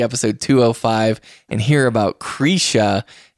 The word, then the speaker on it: Krisha: And it's spelled like Krisha, but it's actually pronounced Krisha. And it's spelled like what? – Krisha, K-R-I-S-H-A.